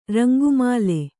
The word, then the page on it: ರಂಗುಮಾಲೆ: Kannada